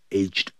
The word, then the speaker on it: aged: 其实那个 aged, 可以省掉 He is 16 now 也就等于 He is 16 years old 或者 He is 16 years of age now 等等 The little boy Who is aged 8 Who is 省掉 Aged 8 现在是 8 岁 Plays the piano very well 钢琴弹得非常的棒那么第二 Aged 这个念成 aged 的时候那么这个时候呢就是表示年迈的后头要放名词，像我们刚前面讲的 one-legged soldier，啊，还有 four-legged animals，就是四只脚动物等等。